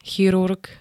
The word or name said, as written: chirurg